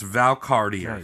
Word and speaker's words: Valcartier: And then he got his crony contractors to construct a new fort at Valcartier. Which I looked up, and that's how you say it. It's Valcartier.